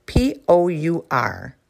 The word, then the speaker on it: pour: The verb pour, P O U R, looks like power, but it's not pronounced that way. It's pronounced pour.